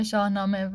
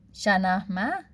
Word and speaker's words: شاهنامه: و بعد اونجا یه مثال زد از شاهنامه که برای من خیلی جالب بود، البته که تلفظش خیلی افتضاح بود از کلمه شاهنامه، و گفتش که شنهمه یه همچین چیزی، یعنی اصلا اشتباه گفت کلا، چه آخرش هم گفته شنهمه،